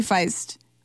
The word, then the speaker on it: Feist: I think it's by Feist. I don't actually know how to pronounce that. If it was, it's gotta be Feist. I thought that Feast and Feist were spelled the same, and I had to like figure that out.